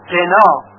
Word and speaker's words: قنا: و در کلمه قنا و قنا اون هم باید با قاف تلفظ بشه نه با غین، پس اگر بگوییم و غنا این هم غلط هست.